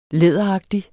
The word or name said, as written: læderagtig